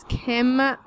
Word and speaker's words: Kim: My name is Kim, K-I-M, Marra, M-A-R-R-A, and this is...